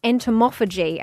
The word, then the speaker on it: entomophagy: I believe it's pronounced entomophagy, and that is eating insects.